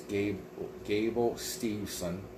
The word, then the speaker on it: Gable Steveson: I'm not mispronouncing it. It's Gable Steveson.